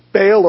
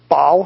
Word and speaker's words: Baal: They worshipped Baal or Baal, however you want to pronounce it.